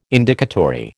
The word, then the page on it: Indicatory: American English